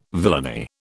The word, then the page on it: villainy: British English